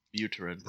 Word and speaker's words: Buterin: Buterin.